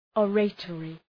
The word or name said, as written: oratory